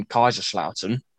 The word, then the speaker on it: Kaiserslautern: It's in Kaiserslautern. I think that's pronounced right. – Yep.